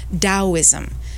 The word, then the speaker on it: Taoism: It was lining up, and as in Chinese medicine, and these ancient practices are based in Taoism. It's spelled T-A-O-ism, sometimes D-A-O-ism, and Taoism talks about living life with flow.